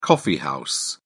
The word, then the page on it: coffee house: British English